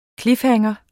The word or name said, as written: cliffhanger